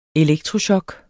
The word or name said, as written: elektrochok